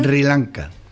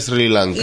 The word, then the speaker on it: Sri Lanka: Se escribe Sri Lanka y se pronuncia Sri Lanka.